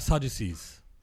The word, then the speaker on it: Sadducees: Sadducees.